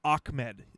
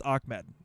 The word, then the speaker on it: Ahmed: Salvin Ahmed. – As a Washington Husky fan, okay. I actually know it's actually Ahmed. So Ahmed. – I know it's Ahmed. It's pronounced Ahmed.